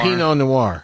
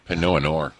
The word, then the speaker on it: Pinot Noir: Pinot Noir. – There you go. – Pinot Noir.